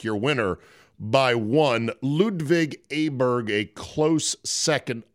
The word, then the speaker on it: Åberg: I hear it's now Åberg is how they're pronouncing it. – They almost said they don't pronounce the G either. Åberg. – Åberg is what I heard.